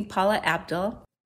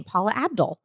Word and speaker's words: Paula Abdul: It's me, Paula Abdul. – No, she didn't say her name wrong. That's just how she says her name, Paula Abdul.